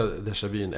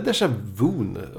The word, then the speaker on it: déjà vu: Det här är ju värsta déjà vyn. Eller déjà vun om man uttalar det fel.